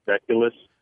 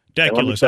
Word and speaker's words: Deculus: However, you didn't even pronounce the man's name right. It's Austin Deculus. – Deculus, I apologize.